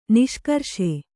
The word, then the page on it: ನಿಷ್ಕರ್ಷೆ: niṣkarṣe